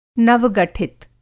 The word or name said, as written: नव-गठित